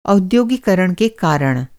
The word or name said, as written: औद्योगीकरण